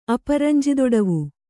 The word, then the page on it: ಅಪರಂಜಿದೊಡವು: Kannada